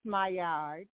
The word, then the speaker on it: Maillard: Well, you know, it's spelled M-A-I-L-L-A-R-D, and it's pronounced Maillard. Or okay. You can say Maillard.